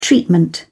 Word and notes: treatment: British English

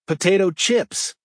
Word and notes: potato chips: American English

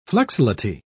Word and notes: flexility: British English

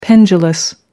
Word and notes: pendulous: American English

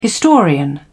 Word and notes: historian: British English

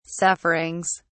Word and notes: sufferings: British English

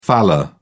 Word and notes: faller: British English

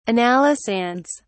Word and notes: analysands: British English

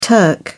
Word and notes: turk: British English

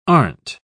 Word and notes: aren't: British English

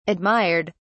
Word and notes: admired: British English

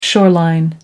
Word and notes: shoreline: American English

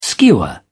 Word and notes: skewer: British English

